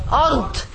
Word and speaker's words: أرض: What I want you to concentrate, how to pronounce the dad. أرض, أرض, not أرض, د, no, not د, د. Da, ard, ard, ard, ard.